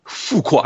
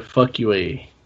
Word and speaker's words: Fuqua: Fuqua. I'm I'm so glad I said that right because okay. – I could have said fuck you a, but I didn't.